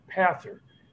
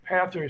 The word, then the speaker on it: Pathr: and it's called Pather. Pather is spelled P-A-T-H-R, which helps retailers and other industries actually utilize spatial intelligence.